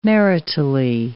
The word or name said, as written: maritally